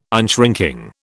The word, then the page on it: unshrinking: British English